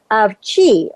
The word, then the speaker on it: qi: And it acknowledges the existence of qi, or it's spelled Q-I.